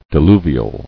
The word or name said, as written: Diluvian